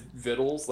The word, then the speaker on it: victuals: But it's, so Bethany was reading about how like why victuals is vittles, like why it's pronounced vittles. And apparently there's like some kind of false, like the C was put in afterwards.